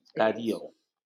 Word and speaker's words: Darío: Rubén Darío, porque tiene un acento en la I. Darío.